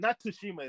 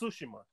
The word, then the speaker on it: Tsushima: Oh, not Tsushima. It's Tsushima. Now that I found out how to pronounce that shit.